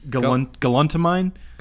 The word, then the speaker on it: galantamine: I want to pronounce it for you. – Galantamine. Galantamine. Galantamine? – Galantamine. – Galantamine. Okay, that was one of the versions I said.